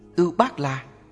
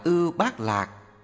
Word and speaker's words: Ưu Bát La: Ưu Bát La, phiên âm từ Phạn ngữ là Upala, cũng đọc là Ưu Bát Lạc hay Ô Bát La, là một loại hoa sen quý màu xanh.